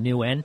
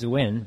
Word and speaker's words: Nguyen: First of all, I want to say that I want people to pronounce my last name Nguyen. – Nguyen? – You know, yeah, okay. – In Vietnamese it is Nguyen, but uh-huh. like I say Nguyen.